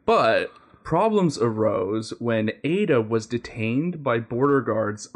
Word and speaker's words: Aida: So in case you haven't guessed it, Ada, whose name is spelled A-I-D-A, is an AI robot.